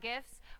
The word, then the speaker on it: GIF: It's pronounced and GIFs. – Pardon me? – Yeah, GIF. You said GIFs. It's an acronym. Acronym's pronunciation is determined by the creator of the said acronym, like a proper name. In this case, the creator of the graphics interchange format, Steve Wilhite, says it's GIFs.